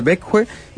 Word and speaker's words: Växjö: En Suecia, en la ciudad de Växjö, pronuncié bien, perfecto, perfecto. Sueco, perfecto, en la ciudad de Växjö,